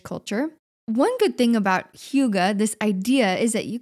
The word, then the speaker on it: hygge: But it's a Danish word pronounced hygge. It's spelled H-Y-G-G-E.